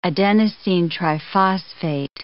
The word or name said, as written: adenosine triphosphate